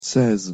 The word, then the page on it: seize: French